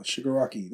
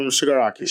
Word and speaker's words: Shigaraki: Shigaraki. There we go. – Shigaraki. – Because no one called Tomaru ever. But So I did say it right. Tomaru Shigaraki.